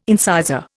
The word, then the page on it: incisor: Indian